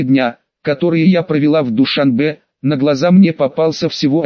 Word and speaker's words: Душанбе: Душанбе произносится с ударением на последнем слоге, можно назвать самой красивой столицей в Центральной Азии.